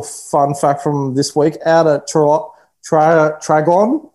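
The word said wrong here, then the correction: Traralgon: out in regional Victoria. I can't pronounce that word. So Traugan. – Traugan.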